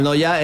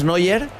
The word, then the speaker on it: Neuer: Manuel Noya era mi abuelo. Yo digo, se pronuncia, es que es Neuer, pero se pronuncia casi igual, sí. – Sí, se pronuncia en Noya.